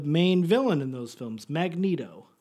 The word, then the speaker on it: Magneto: Well, you would think it would be Magneto, but it's not. It's pronounced Magneto.